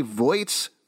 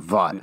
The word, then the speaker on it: Vought: Vought's wife. – What? – You said Voight. – Voight. I've been pronouncing it wrong. Vaught.